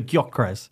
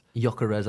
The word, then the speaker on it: Gyökeres: The two big names that departed, so Gustavo Hamer, and am I going to absolutely annihilate this one, Victor Gyökeres. – Gyökeres, I think it's a silent G.